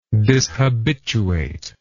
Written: dishabituate

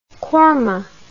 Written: korma

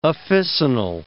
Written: officinal

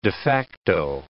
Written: de facto

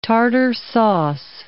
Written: tartar sauce

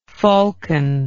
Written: falconoid

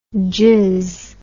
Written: jizz

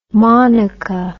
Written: monica

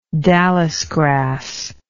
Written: dallisgrass

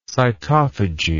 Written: cytophagy

